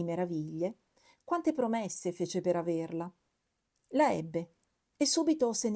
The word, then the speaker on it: averla: Si pronuncia averla con la E aperta, come quella che utilizziamo quando pronunciamo la terza persona singolare del verbo essere.